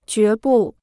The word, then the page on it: 决不: jué bù